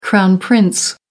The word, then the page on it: crown prince: American English